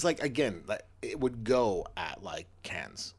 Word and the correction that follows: Cannes: Like, it would totally. – Can. I know. – It would totally fit in. – Yeah, no, I'm saying that I just mispronounced Cannes.